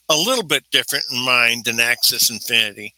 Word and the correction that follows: Axie Infinity: Axis, am I saying that wrong? Probably right. – Axie Infinity, thank you.